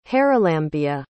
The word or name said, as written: haralambia